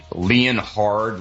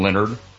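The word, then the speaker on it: Leonhard: You know who's not on my ass list, and it goes to the AFC East, is Leon Hard Leonard. How do you say his name? He's one of my favorite coaches. Jim Leonard. – Dude, stop. – He's spelled like Leon Hard.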